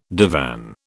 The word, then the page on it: divan: British English